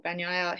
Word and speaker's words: Bagnaia: But the big one was when we saw that, and then the camera panned to the next corner, and Bagnaia. I know I'm saying it right, but it's a struggle. Bagnaia, who is the championship leader, he had a stomach curdling moment.